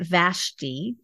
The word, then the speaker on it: Vashti: The queen, and I pronounce it Vashti. – Yes. – Is that how you, okay. – Yeah. – So Vashti,